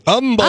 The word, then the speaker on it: Humble: Because we don't like H's in Humble, Texas. – That's how you know someone's moved there from out of town or visiting, right? They say it wrong.